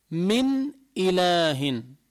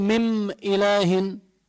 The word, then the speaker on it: من إله: حنعطي مثال للتذكير، كما لو قلنا من إله، مثلا قوله تعالى وما من إله إلا الله الواحد القهار، من إله، من إله، ما بقول من إله، ما بطلع غنة ولا بقلبها ميم، بقول مم إله كمان لا، ولا بضغمها بالهمزة مئ إله، بقول لا، إنما تلفظ واضحة من إله.